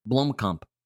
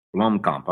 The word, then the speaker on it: Blomkamp: The Neil Blomkamp, I don't know how to say that. – Blomkamp. – His film from the director of District 9 and starring Matt Damon. How do you say it? – Blomkamp. – Blomkamp. All right.